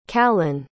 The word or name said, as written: callen